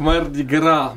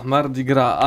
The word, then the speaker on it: Mardi Gras: tak, Mardi Gras, Mardi Gras.